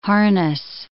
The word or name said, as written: harness